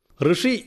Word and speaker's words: ഋഷി: ഇർ, ഇറു, എറു, റു തുടങ്ങിയ വികലമായ ഉച്ചാരണമാണ് പലപ്പോഴും കേൾക്കാറുള്ളത്. ഋഷി എന്ന പദം 10 പ്രാവശ്യം ഉരുവിടുക, തുടർന്ന് ഷി ഒഴിവാക്കി ഉച്ചരിക്കുക. അതാണ് ഋകാരത്തിൻ്റെ ഉച്ചാരണം.